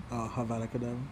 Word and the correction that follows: Avada Kedavra: There's no H in front of the spell. It's Avada Kedavra.